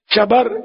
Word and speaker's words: شبر: اعلیٰ حضرت نے اس کا تلفظ یہ بیان کیا، شبر، شبیر، مشبر.